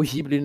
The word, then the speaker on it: Ghibli: estúdio Ghibli, ou Ghibli, né, que é o jeito certo de falar, mas eu aprendi Ghibli e eh, não consigo falar de outro jeito.